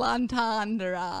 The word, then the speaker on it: entendre: Entendre? It's got that weird French R at the end, yeah. Like entendre.